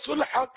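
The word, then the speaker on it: صلح: سلح کر لے، وہ سلو نکل گیا، کیونکہ ہمارے یہاں عام طور پر سلو بولتے ہیں، یہ اس کا تلفظ سلو نہیں ہے، سلح عربی لفظ ہے، سلح۔ صلو علی الحبیب صلی اللہ علی محمد۔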